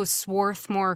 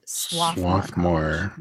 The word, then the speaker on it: Swarthmore: And Swarthmore, just so you know, is spelled Swarthmore, but when you're from there, the R is silent.